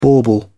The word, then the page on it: bauble: British English